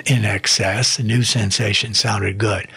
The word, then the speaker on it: INXS: And I promise you, Davey, I promise you, Terry, sit back and relax, because as soon as they spell it INXS, but it's In Excess off the Kick album, we'll stop.